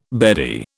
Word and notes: beardie: British English

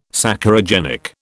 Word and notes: Saccharogenic: British English

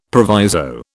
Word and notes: proviso: British English

